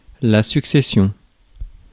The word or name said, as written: sequence